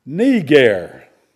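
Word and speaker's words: Niger (Νίγερ): Well, that Greek word behind that word Niger, as we say that in English, here's how it's pronounced, and you listen to me carefully. Niger. That's the Greek, Niger. Well, it means black.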